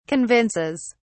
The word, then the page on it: convinces: British English